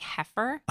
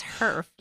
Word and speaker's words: HERF: I keep wanting to say like heifer, like cow, but it's HERF.